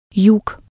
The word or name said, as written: yug